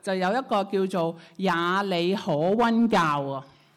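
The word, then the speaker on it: 也里可温: 雅里可温呢，其实它的发音呢，其实就像耶和华上帝，耶和华那个发音。 – 耶里可温就是耶和华上帝的，你们可以试试这个发音很像。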